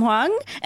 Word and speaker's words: Huang: This week, we're really excited to welcome filmmaker, actor, probably many, many things, Tom Huang. Am I seeing your name right? Because I just went for it. – That's right. Tom Huang. It rhymes with Bong.